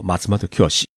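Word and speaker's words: マツモトキヨシ: 像日本著名的药妆店的连锁店松本清，日文是念作 Matsumoto Kyoshi，到处都有。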